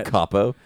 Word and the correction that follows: El Chapo: Did you say El Capo? – It's El Chapo. – El Chapo. I will not answer. – Hey, I'm not answering that question. – You have to pronounce the H.